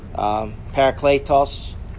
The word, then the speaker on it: Parakletos: Parakletos is the Greek pronunciation for Paraclete.